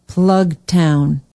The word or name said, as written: plugtown